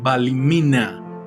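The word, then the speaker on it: Ballymena: Él nació en Balimina. Eh, eh, consulté la pronunciación, o sea, se dice así.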